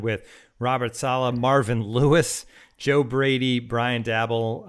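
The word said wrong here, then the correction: Daboll: Eric Bieniemy. Dable, sorry, I did it again.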